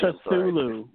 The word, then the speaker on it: Cthulhu: By the way, guys, I'm pretty sure the T is silent in Cthulhu. That's just bothering me. – Cthulhu.